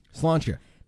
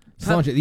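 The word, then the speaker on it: Sláinte: S-L-A-I-N-T-E. – Slantia. Slantia, the Irish store.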